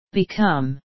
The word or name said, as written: become